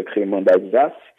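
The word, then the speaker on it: crémant d'Alsace: Então vamos lá ao nosso vinho de hoje, nosso vinho espumante, que é o, é, a gente escreve em português cremante d'Alsace, né? Então em, em francês se pronuncia cremant d'Alsace, né?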